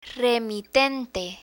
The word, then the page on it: remitente: Spanish